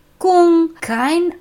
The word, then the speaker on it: 功课: Die erste Silbe ist Gung, die zweite ist Ke. Gung, kein einziger Anflug von Streben, nur Ke.